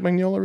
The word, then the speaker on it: Mignola: I didn't know anything about Mike Mignola, really.